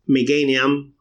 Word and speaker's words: Meganium: Eh, ahora le pusieron la coloración de Meganium o de Meganium, como se pronuncia en inglés, nombre horrible.